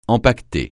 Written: empaqueter

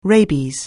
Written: rabies

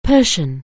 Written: Persian

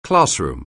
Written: classroom

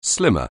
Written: slim

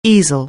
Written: easel

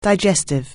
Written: digestive

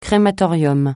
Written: crématorium

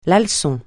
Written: leçon

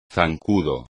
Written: zancudo

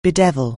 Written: bedevil

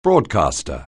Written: broadcaster

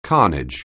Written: carnage

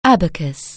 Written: abacus

